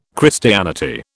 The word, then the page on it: Christianity: British English